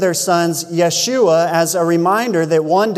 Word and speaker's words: Yeshua: Jesus in Hebrew is pronounced Yeshua, which means salvation.